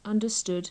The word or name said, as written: understood